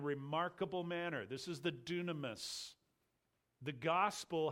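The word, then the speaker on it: dunamis (δύναμις): I'll pronounce it for you. It's dunamis, and dunamis comes from the idea of dynamo or dynamite.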